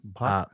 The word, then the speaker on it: Bhakta: Bakta. How do you spell it? B-H-A-K-T-A. – Bakta.